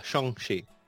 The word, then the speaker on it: Shang-Chi: Saw Shang-Chi. – Shang-Chi. – Sure. – I found out how to properly pronounce it. – I know. – I was doing the same thing.